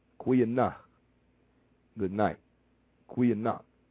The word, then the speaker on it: goeienag: G-O-E-I-E-N-A-G. Kwe, the G is pronounced Q, Kwe, Kweanach. – Kweanach. – Kwe, it's, see, and it's at like, it's kind of like they put an A onto it, but it's not there. The A is silent, but it's there.